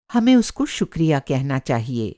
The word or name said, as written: शुक्रिया